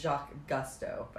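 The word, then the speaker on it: Jacques Cousteau: It's Jacques Gusteau, by the way.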